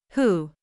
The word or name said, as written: who